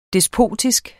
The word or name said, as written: despotisk